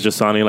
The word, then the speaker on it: Jasani: Like, my name is Jasani, like the water with a J.